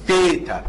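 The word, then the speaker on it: ثيتا (θήτα): بعد كده ايه الحرف ده، حرف مشهور في الرياضة، بيتنطق باليوناني بميلة ثيتا. ثيتا بيتنطق زي حرف الثاء عندنا في العربي، او التي اتش في الانجليزي، بس بالانجليزي التي اتش بتدي على ذه ذه ذال، لكن دي ثاء ث ثيتا.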